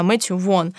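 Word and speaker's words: Vaughn: Мэтью Вон, если я правильно помню, как читается его фамилия. Да, Мэтью Вон, который, собственно, сделал «Первый класс».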